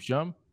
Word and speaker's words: Schum: Is it Shum or Shum? I don't even know how you pronounce his name. Shum, it's Shum, S-C-H-U-M.